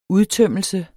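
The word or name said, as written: udtømmelse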